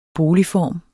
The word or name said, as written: boligform